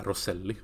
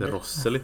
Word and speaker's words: Rosselli: Vi måste förut börja säga Rosselli, som han faktiskt heter. Vi som alltid klagar på att folk inte kan uttala våra spelare. – Ja. – Lex Chey Genoway. – Mm, precis. – Men varför fan har vi alltid sagt Rosseli när han inte heter så? – Nej, jag vet.